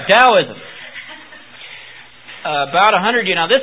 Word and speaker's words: Taoism: And Taoism is spelled Taoism with a T, but it's pronounced with a D sound, Taoism.